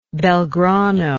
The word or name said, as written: belgrano